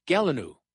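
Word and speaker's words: gallinule: And then there's the, this one is a tough one. It's pronounced, it's the common gelinoo, and it's another marsh bird from the September 1st to the 9th, 15 possession, daily limit rather.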